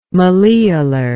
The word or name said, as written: malleolar